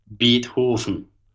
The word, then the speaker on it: Beethoven: Yeah, but how do you say Beethoven in German, by the way? – Beethoven.